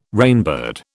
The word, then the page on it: rainbird: British English